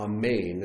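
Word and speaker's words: amen (אמן): The first one, the three-letter word, is the most common Hebrew word in the English language, is the word amen, or amen, as it's pronounced in Hebrew.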